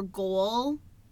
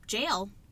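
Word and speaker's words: gaol: So it was originally called the Carleton County Jail, spelled G-O-A-L. So I recently learned while doing research for this podcast that a lot of people think that that is like Gale or Goal. Like, cool. – However you want to say it, but G-O-A-L was actually pronounced jail, just like J-A-I-L.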